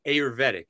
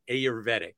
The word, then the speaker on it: Ayurvedic: I pronounce it Ayurvedic. Isn't that the way to pronounce it? Ayurvedic? – Well, it's A-Y-U-R-V-E-D-I-C.